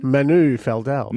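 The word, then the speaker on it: Manu Feildel: Did you see the news that Manu Feldal from Channel 7 has parted ways? – How do you say his name again? – Manu Feldal.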